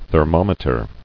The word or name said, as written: Thermometrograph